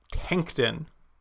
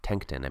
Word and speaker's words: Tencton: Tankton. – Yeah, T-E-N-C-T-O-N. Tankton, I believe is how it's pronounced.